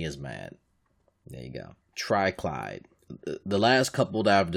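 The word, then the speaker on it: Triclyde: So, Birdo, Mouser, Fry Guy, and Triclide, Clyde, and that's how you say his name, are all bosses in the game.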